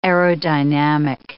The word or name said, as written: aerodynamic